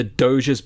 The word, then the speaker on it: Doge: Part of our visit included the Doge's palace. Yes, bingo, it was 100% pronounced Doge, i.e. not like the G in dog," and she has provided a dictionary definition here as well.